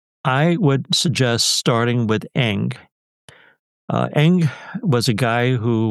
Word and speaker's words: Ng: And that's spelled NG, right? – NG, exactly.